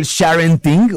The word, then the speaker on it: sharenting: Bueno, antes, redefinamos esto del sharenting o sharenting. Uh-huh. ¿Cómo se pronuncia correctamente? – Sharenting. – Gracias. Sharenting. Pues esta es la acción de compartir por parte de papás y mamás fotografías de nuestras hijas e hijos de manera profusa.